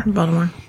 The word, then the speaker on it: Baltimore: I say Baltimore like that now because that's how they say it on The Wire. – Baltimore.